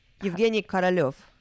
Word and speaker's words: Yevgeny Korolev: Yevgeny Korolev, I think. – You see, I'm so glad you're here because this is your native tongue, and I'm so terrible. – Please. Korol is a king.